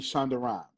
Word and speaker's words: Shonda Rhimes: You don't want to be an actor, you want to be Shonda Rhimes. Is that a name? Did I say it right? – Yep.